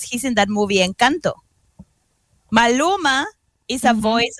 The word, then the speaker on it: Maluma: Malo- Maluma, Maloma. I can't even pronounce them. Like I'm so lost. – Perla, you're showing your age. Maluma.